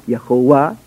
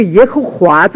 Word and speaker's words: 耶和华: Adonai Yehovah，听见吗？同样的读音，对吧？所以呢，这个耶和华这个读音啊是不准确的。